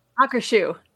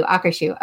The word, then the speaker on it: Akershus: Akershus. – Thank you. Akershus.